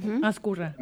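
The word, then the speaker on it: Ezcurra: Ascurra. – Uh-huh. No, Ascurra, está bien, Ascurra. Está bien como lo pronunciaste.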